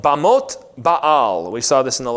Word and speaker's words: Baal: That word means, Bamot means high places, and Baal is Baal, but you would have a little break in it. Baal is how you'd pronounce that.